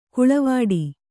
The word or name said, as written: ಕಾಳವಟ್ಟ